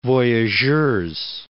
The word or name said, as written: Voyageurs National Park